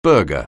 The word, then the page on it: burger: anglais